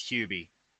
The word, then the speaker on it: Hubie: Yeah, it's Could be Hubby Halloween or whatever. Looking at how it's written, it would be pronounced as Hubie.